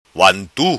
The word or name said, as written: vantu